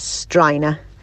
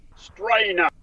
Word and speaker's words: Starinagh: Here in RD, I would pronounce that as Strina in County Loud. – Interesting. I'd say that's quite near the mark there for sure. Have we another one there, Louise? – Strina.